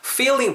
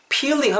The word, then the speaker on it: feeling: feeling 발음하실 때 일단 F 사운드 살리셔야 됩니다. peeling 하면 안 돼요, 여러분. peeling 하면 껍데기 같은 거 벗겨지고 벗기는 거예요. peeling 막 이렇게 사과 같은 거 껍질 까는 거예요.